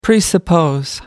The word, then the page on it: presuppose: American English